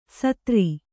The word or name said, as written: ಸೂದ್ರ